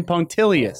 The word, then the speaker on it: punctilious: Punctilious. – That till threw you off right in the middle.